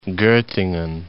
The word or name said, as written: Gottingen